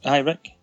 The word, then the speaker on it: Ricq: So we're going to talk about Dead Shack from 2017, directed by Peter Rick? I have no idea how you pronounce that. R-I-C-Q. Give me your wisdom. – R-I-C-Q. – That's his second name. – Rick. – Just Rick? – I think so.